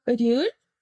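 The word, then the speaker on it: рюль (리을): Вообще ее произносят как рюль, рюль. Это прям название буквы. То есть у нас же есть, например, С — это С. – Да, да, да, да, да, да, да. – Да. И вот эта буква называется рюль. Она произносится Р или Л уже в зависимости от того, где она стоит в слове.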